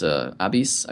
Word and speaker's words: Abis: We worked with Marco Abis, who was Abis, I believe is the correct pronunciation.